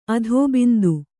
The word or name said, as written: ಅಧೋಬಿಂದು